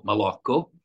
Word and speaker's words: Maloco: So my name is, to pronounce it for you correctly, my name is Michael Malocco.